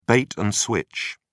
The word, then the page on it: bait and switch: British English